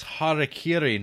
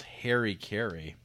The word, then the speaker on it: Harakiri: It's pronounced Harakiri, not Harry Carey.